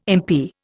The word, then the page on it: MP: Indian